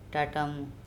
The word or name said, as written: TaTamu